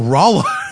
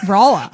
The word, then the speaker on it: Rolla: No, Rolla. Well, it looks like Rolla, but yeah. – Like rock and Rolla? – Yeah, right.